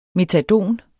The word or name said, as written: METADON